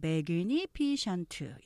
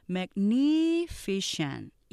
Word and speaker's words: magnificent: 그다음에 이렇게 긴 단어 우리가 magnificent 이렇게 하지만 음절 보시면 magnificent 이렇게 사음절로 되면서 여긴 두 번째 액센트 있어요. 그래서 익숙하게 들리면 좀 반복해서 하셔야 돼요. magnificent 이렇게 발음하시면 정확하게 하실 수 있고요.